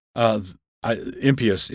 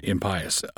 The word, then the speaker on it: impious: Impious is the word impious, I-M-P-I-O-U-S.